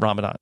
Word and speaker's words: Ramadan: Is it wrong when I pronounce it Ramadan? – No, Ramadan is the English way of saying it. Ramadan is just the. You have to add that when you're speaking it in the Arabic.